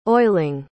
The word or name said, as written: oiling